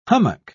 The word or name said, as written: hummock